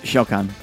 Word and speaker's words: Shao Khan: Shadow Khan, if I remember his name, if I'm saying his name right? – Shao Khan. – Yeah, Shao Khan.